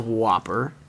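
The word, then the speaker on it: WOPR: There is no E. It's W O P R. It's pronounced oh. Whopper.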